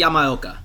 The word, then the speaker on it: Yamaoka: Yamaoka.